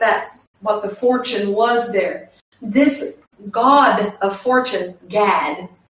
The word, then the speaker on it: Gad: They think, see, Christians and Hebrew roots think that the Jews, all the Jews, are calling on God, G-O-D. They're not. They're calling on Gad, but it's pronounced God. But it's G-A-D.